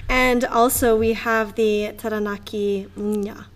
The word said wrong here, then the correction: Taranaki Maunga: Taranaki Maunga.